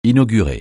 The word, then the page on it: inaugurer: francais